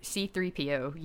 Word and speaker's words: C-3PO: You're saying all the same letters and numbers in this great sequential order. Yes, it is C-3PO. You did say that correctly.